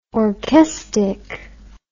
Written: orchestic

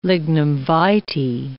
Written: lignum vitae

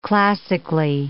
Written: classically

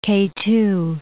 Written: K2

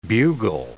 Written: bugle

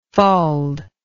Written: fauld

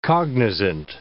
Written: cognizant

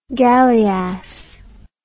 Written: galliass

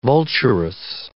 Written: vulturous

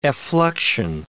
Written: effluxion

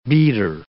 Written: beater

